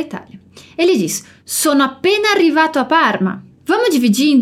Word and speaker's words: arrivato: Aqui a gente se concentra na pronúncia do R, em arrivato. A gente tem uma pronúncia bem forte, a pronúncia dos dois R's, que pode ser meio desafiadora porque poucas pessoas a utilizam em português.